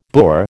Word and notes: boor: American English

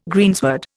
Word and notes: greensward: Indian

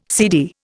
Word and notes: seedy: Indian

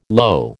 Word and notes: lo: American English

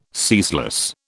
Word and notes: ceaseless: British English

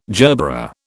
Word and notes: gerbera: British English

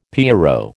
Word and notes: pierrot: American English

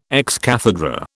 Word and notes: ex cathedra: British English

